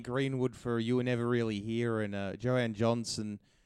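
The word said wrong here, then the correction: Jóhannsson: Johansson, the late great.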